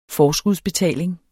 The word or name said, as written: forskudsbetaling